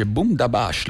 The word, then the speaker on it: Boom Da Bash: Boom Da Bash, l'ho detto bene? Detto bene, sì? – Sì, ho detto bene.